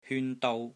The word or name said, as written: HYUN1 DOU1